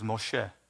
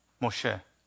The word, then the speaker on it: Moshe: But the Hebrew pronunciation, of course, is Moshe. Moshe. That's the exact identical pronunciation.